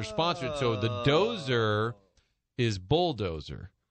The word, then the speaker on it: Dozer: And I'm asking people, how do you pronounce it? And they said, Dozer, as in bulldozer.